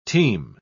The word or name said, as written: teem